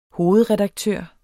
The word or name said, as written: hovedredaktør